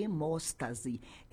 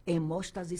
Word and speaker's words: hemóstase: Você vai fazer um dos exames que é o chamado de hemóstase. Hemóstase se escreve com H.